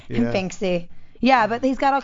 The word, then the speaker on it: Banksy: But Bansky's, yeah, look up Bansky, B-A-N-K-S-Y, Banksy. – Oh, Banksy? – I always read it. I've been saying it wrong. – Yeah. Banksy then? – Banksy. Oh, my God. I've always said Bansky.